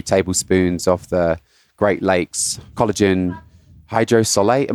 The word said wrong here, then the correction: hydrolysate: Am I saying that correctly? Is that one of the things I've ever read? Hydrolysate. – That, what Zach said.